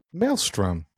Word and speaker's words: Maelstrom: I can't ever pronounce this. Is it the Maelstrom? – Maelstrom. – Yeah. Look at me go.